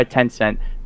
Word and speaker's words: Tencent: It's made by Tencent. Sorry, I want to make sure that I said that right. Yes, it is made by Tencent.